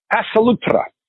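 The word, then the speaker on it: Pass a Loutre: That's spelled P-A-S-S, capital A-U-L-O-U-T-R-E, Passalutra.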